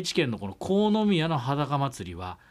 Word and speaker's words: 国府宮: 国府宮と書いて国宮と読むそ